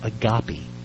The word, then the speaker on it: agape: The Greek word that Paul used here for love is agape. Some people pronounce it agape.